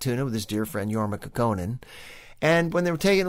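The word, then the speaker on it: Jorma Kaukonen: I can even pronounce the guitar player's name, Jorma Kekkonen.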